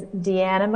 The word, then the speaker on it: Deanna: Um, so, my name is Deanna McLeod.